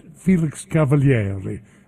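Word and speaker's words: Felix Cavaliere: Cousins, our guest tonight, Felix Cavalieri. And I think I'm one of the only ones that pronounces the name, well, properly, like we would in Sicilian, I guess.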